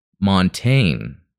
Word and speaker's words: Montagne: Montagne is pronounced Montaigne. Oh, okay. Like the word train without the R and with a short O sound.